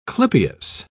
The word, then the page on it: clypeus: British English